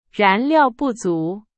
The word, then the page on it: 燃料: Chinese